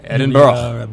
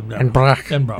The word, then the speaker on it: Edinburgh: You just have to put a little emphasis on the end of it. It's like, you just get a little lazy with it. Edinburgh. – Edinburgh. – Yeah. – E-D-I-N-B-U-R-G is Edinburgh to me, like Pittsburgh should not be Pittsburgh.